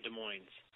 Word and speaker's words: Des Moines: Thank you for calling the city of Des Moines. Des Moines, Des Moines, Des Moines. – So which is it? I said I always pronounce the S.